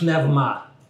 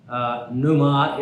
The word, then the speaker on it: pneuma: So the Greek pronunciation of pneuma is pneuma, because they do not have silent letters. Well, so what? We don't speak Greek, we're speaking English. Pneuma is the way that we pronounce it.